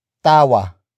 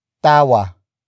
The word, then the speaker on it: tawa: Tawanan is spelled as T-A-W-A-N-A-N. It means to laugh at someone or something. Tawanan comes from the root word tawa. Tawa. Tawa. Which is spelled as T-A-W-A.